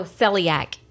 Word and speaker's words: celiac: A new study shows that people with, I don't even know how to pronounce this, coliac disease shouldn't be afraid of kissing gluten eaters. – Oh, celiac.